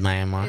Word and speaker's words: Myanmar: Are you sure it's Myanmar? – Yeah, that's how they say it.